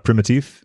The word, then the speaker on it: Primitive: and Primitive. – Yeah. – Okay, I want to make sure I pronounced that correctly. – Very good, actually. It's like French inflection.